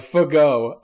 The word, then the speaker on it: Fagot: Last name spelled F-A-G-O-T. – That's exactly right. – And pronounced Fago.